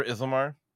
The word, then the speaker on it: Ithilmar: Mostly I wanted to make sure I was saying it right, because I often get it mixed up as where the TH and the L are. But yeah, Ithilmar weapons says when engaged in combat, a model with a special rule that is fighting.